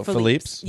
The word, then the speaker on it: Philippe's: So, have you been to, I'm going to pronounce it wrong because we've pronounced it one way my whole life and it's different. Felipe's in downtown LA. – Oh, Philippe's? – Yeah, we've all called it Felipe.